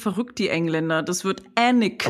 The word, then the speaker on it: Alnwick: Ähm, aber es, also es wird Alnwick geschrieben, aber Annick ausgesprochen.